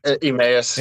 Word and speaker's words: Emmaus: Y'all were from Emmaus. – Emmaus.